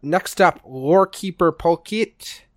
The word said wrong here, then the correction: Polkelt: Polkett? I don't know how you pronounce it. Polkelt? – Polkelt, I think. – Yes, Polkelt.